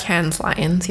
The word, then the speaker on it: Cannes Lions: And this song was entered into, I believe it's pronounced the Cannes Lions. Basically, Cannes Lions, yes. – The Cannes Lions, there you go.